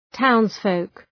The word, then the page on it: townsfolk: ‘taʋnz,fəʋk